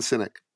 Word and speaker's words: Sinek: That's how you say his last name? – Simon Sinek.